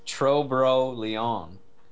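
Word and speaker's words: Tro-Bro Léon: It's Tro-Bro Leon race that happened in France yesterday.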